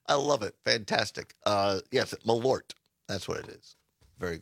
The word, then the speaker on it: Malort: But Carol says it's one syllable. Malort.